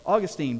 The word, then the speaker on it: Augustine: As Augustine famously said, or Augustine, depending on how you want to pronounce his name, famously put it, command what you will.